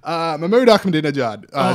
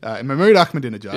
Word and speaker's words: Mahmoud Ahmadinejad: Anyway, Mahmoud Ahmadinejad, speaking of Twitter. – That was fantastically pronounced. – Thank you very much. – Do it again. – Mahmoud Ahmadinejad.